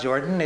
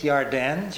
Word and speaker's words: Yarden: You see, what happened to us was the German J never got adequately translated. So the Jordan is not Jordan, it's Yarden. Jerusalem is Yerushalayim. Jesus is Yeshua.